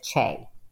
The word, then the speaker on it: Che: I've really heard Shay or softer Che. It really does not matter. I'll just worry when they don't call me at all. So I'm just happy. Yes, either one is fine. But really, the spelling pronounces it Che. – So it's Che, be yeah. all end all, not necessarily Shay, although you're okay with that, but it's Che.